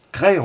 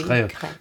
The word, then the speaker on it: Crest: Et je voudrais signaler aussi que vous êtes à Jazz à Crest, Cray on dit. – Oui, Cray. – Ça se prononce Cray, oui.